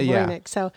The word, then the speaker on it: Voynich: Okay, we're going to go with Voynich.